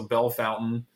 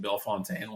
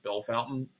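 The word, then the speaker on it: Bellefontaine: I'm also, selfishly, I love the weird, stupid thing that Ohio does where you take, like, French-sounding place names and pronounce them in the most butchered way possible. So Bell Fountain, beautiful. If we can get national people, like, trying to figure out why you're pronouncing Bell Fontaine like Bell Fountain, I love it.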